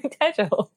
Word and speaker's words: Tejel: But then one teacher said it wrong and called me Tejel. And then I'm like, oh, wait, no, that's actually how you do say my name.